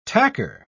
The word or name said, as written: tacker